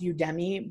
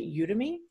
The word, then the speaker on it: Udemy: I just downloaded a practical ethical hacking course from, I thought it was pronounced Udemy, but the instructor pronounced it Udemy.